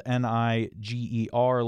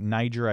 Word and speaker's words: Niger: The country is spelled N I G E R. Looks like Niger. I believe it's Niger, though.